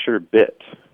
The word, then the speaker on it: sherbet: And I went to my neighbor's house, who's like my grandpa, and he corrected me and said, no, it's sherbet with no second R at the end.